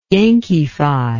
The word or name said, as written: yankeefy